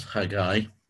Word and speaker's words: Haggai: so that's Haggai, which really does convince me it's only two syllables.